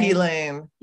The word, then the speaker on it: Ghislaine: It's not Ghislaine. – Ghislaine. – Yes.